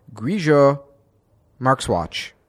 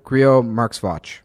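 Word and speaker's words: Grillo-Marxuach: It says on his website, he was born and raised in Puerto Rico, and his name is pronounced Javier Grillo Markswatch. So Javier Grillo Markswatch. – Mm-hmm. – Okay, that's how we're going to say it.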